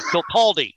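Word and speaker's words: Kirkcaldy: And the other guest, a very special one tonight, calling in from all the way from Kilkarney in Fife, Scotland. Did I say that right? – Kilkaldy.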